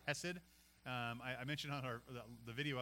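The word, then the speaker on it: hesed (חסד): It actually is spelled in like a lot of the Strong's concordances, C-H-E-C-E-D. And when you say hesed, you're supposed to say it with a little bit of phlegm at the beginning.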